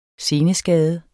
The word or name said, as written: seneskade